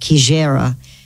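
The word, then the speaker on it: Xigera: And that's X-I-G-E-R-A, pronounced Kijera Safari Lodge.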